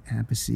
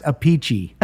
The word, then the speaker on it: Appice: They're brothers, and one of them pronounces the name Appice, and one of them pronounces their name Apice. And they, in an interview... They asked the sister, look, Carmen says this, Vinny says this, how do you pronounce it? And she said, it's pronounced a peachy.